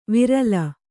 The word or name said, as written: ವಿರಲ